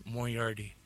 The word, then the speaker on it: Moriarty: How do you say it again? – Dr. Moriarty. – Moriarty. – Hey, you'll get it, man. – Moriarty. – You'll get it. – Yeah, you said it.